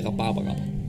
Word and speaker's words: Rhabarrab: Fordi jeg har hørt mange forskellige udtaler, men jeg siger Rhabarrab.